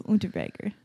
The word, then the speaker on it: Unterweger: Yeah, it's a W. Unterweger. Nice.